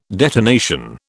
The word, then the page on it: detonation: British English